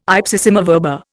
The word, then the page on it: ipsissima verba: Indian